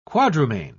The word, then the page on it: quadrumane: British English